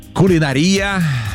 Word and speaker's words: кулинария: Но смотрите, на самом деле варианты называют равноправными. Как, кстати, пиццерия и пиццерия. Вот так вот, по старой норме, старая норма, там до 2000 года ударение было на третье слово. Кулинария. Представляете? Это старая норма. Но сейчас кулинария в некоторых орфоэпических словарях помещается как устаревшая.